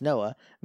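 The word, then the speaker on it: Noé: And then lastly from Christina, Noah, N-O-E, but pronounced Noah, means misty rain.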